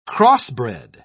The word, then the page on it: crossbred: British English